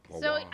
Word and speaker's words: Poirot: Hercule Poirot. Poirot. I can always say it wrong. Poirot.